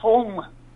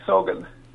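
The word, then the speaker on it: Sogn: Well, the locals call it Song, not Sogan. But we called it Sogan because we couldn't pronounce the other word.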